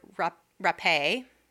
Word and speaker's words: Rappé: We're talking about the rape and murder of Virginia Rappé, mm-hmm. is how you say her name. I made sure it wasn't rape. – 'Cause, yeah, it's not rape.